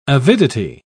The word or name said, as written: avidity